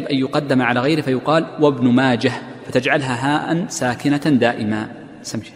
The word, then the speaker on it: ابن ماجه: أيضا من الفوائد تتعلق بهذه الجملة من كلام المصنف قوله ابن ماجه، والمشهور عند المحدثين أن الهاء في ابن ماجه هاء ساكنة، وكثير من الناس يجعلها تاء مربوطة فيقول روى ابن ماجة، وهذا المحدثون في نطقهم لا يصححونه، لا يصححون هذا الشيء.